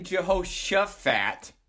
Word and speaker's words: Jehoshaphat: Well, you said Suffat, and it's actually Shuffat as it's written. – Oh, for crying out loud. – Right? – Jehoshaphat.